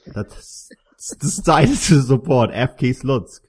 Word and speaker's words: Slutsk: But if you spell it in English, it's pronounced as Slutsk.